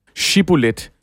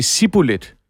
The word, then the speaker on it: Shibboleth: Men hele tingen er, at for gileaditterne, der udtaler man starten af det som Shibboleth. – Ja, med et H.